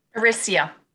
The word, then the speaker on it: Orysia: Orysia.